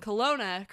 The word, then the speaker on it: Kelowna: And I'm proud of myself for pronouncing Kelowna correctly. I never would have if I didn't think that it was spelled like colony with an A.